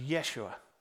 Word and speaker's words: Yeshua: And even the name, as we just saw in the video we just watched, even the name Jesus, or in Hebrew or Aramaic, it would have been pronounced Yeshua.